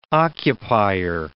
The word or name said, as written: occupier